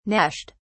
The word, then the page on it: gnashed: British English